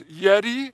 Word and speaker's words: Iddo: His dad's name is spelled I-D-D-O, but it's Yedi. His name is Yedi, and he accompanied Zerubbabel and Joshua, the high priest, back to Jerusalem.